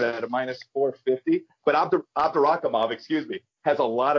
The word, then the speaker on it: Abdurakhimov: And maybe you can help me pronounce this guy's name, Abdurakhimov, or something. What's going on in that fight? – Shamil Abdurakhimov. – Yeah, that's what I was trying to say.